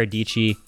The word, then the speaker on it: Dietschy: let's take a quick break, and then when we'll come back, we'll have Sara Dietschy, rhymes with peachy, on the podcast, and we'll talk a little bit about YouTube starting up, advice, the whole game.